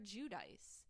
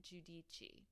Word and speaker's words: Giudice: we always called her Judice. It was always Teresa Judice. And then at some point, she decided to tell the truth about her last name and say, oh, we actually pronounce it Judici.